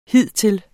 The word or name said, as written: hidtil